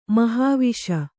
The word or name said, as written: ಮಹಾವಿಷ